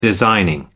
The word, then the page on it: designing: American English